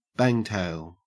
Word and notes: bangtail: Australian English

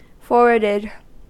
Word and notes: forwarded: American English

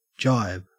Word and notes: jibe: Australian English

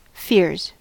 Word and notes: fears: American English

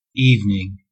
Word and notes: evening: American English

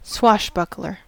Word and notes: swashbuckler: American English

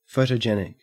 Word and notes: photogenic: Australian English

